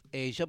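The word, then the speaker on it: Asya: It's pronounced Asia, but it's spelled very differently than what you spelled it.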